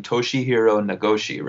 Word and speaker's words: Toshihiro Nagoshi: Toshihiro Nagoshi, right? – That's what I would say.